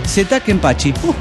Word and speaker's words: Zeta Kenpachi: Soy el Zeta Kenpachi. – ¿Cómo estás, Zeta Kenpachi? – Sí, Zeta Kenpachi. Para comenzar, si mi nombre se dice como se escribe, Zeta Kenpachi.